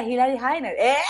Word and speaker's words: Hillary Hiner: Eh, estoy con Hilary Hiner. ¿Así está? ¿Lo dije bien, Hilary? Mm, no, pero... cómo se dice. Hilary Heiner. Heiner. Ah, perfecto.